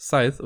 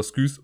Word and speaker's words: Scythe: Ja, ich habe Scythe noch nie gespielt und ja, es heißt Scythe und nicht Scythe oder Skythe oder wie auch immer. Mir stellen sich immer die Fußnägel hoch, wenn Leute das Scythe aussprechen. Aber okay, äh, ich will auch gar nicht sagen, dass meine Aussprache jetzt hier immer das Top-Notch-Beste ist irgendwie, aber Scythe ist auf jeden Fall näher dran als Skys.